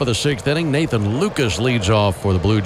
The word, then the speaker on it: Lukes: And an 0-1 count on Lucas. Spelled L-U-K-E-S. Looks like Nathan Lukes, but pronounced Lucas.